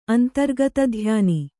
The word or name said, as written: ಅಂತರ್ಗತಧ್ಯಾನಿ